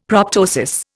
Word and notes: proptosis: Indian

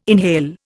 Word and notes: inhale: Indian

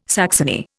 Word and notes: saxony: Indian

